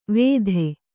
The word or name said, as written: ವೇಧೆ